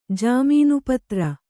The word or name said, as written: ಜಾಮೀನುಪತ್ರ